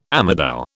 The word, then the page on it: amadou: British English